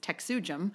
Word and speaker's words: TexUJM: So before I go like back into the why, I will say that for the Texas UU Justice Ministry, which we pronounce the acronym as TexUJM,